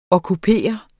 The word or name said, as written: okkupering